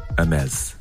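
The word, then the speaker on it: Hermès: Hermes.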